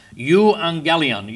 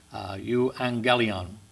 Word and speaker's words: Euangelion: And the GG is pronounced like NG. Euangelion.